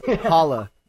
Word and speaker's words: Holo: It's a replacement for Holo, yellow, whatever. – Holo. Anyways, it's pronounced Holo.